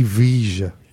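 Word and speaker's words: Villa: And it was a week before we had our last Christmas show with Ozzy Ardiles and Ricky Veer, and he went, it's not Ricky Veer. The Argentinian pronounce is Ricky Veja.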